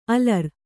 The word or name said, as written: ಅಲರ್